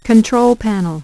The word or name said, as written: control panel